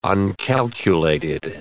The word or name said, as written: uncalculated